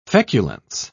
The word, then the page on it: feculence: British English